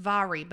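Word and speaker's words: Mhairi: Her name is pronounced Vahri, but it's spelled M-H-A-I-R-I. I had to Google.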